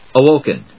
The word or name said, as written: awoken